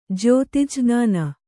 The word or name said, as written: ಜ್ಯೋತಿಜ್ರ್ಞಾನ